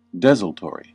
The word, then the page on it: desultory: American English